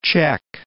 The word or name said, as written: Cech